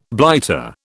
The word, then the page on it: blighter: British English